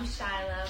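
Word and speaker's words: Shylah: I'm Shyla. Follow me on Instagram at SoShyla. – Sheesh. Spell it for him, though. – S-O-S-H-Y-L-A-H.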